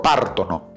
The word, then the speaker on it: partono: Io parto, tu parti, lui parte, noi partiamo, voi partite, loro partono. Acá también, a ellos, a loro, l'accento lo hacemos caer en la terzultima sillaba.